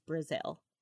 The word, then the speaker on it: Breasal: Yeah, but it's spelled in Gaelic. It's spelled B R E A S A L. – Okay, but when you say it out loud, it's spelled it sounds exactly the same as Brazil.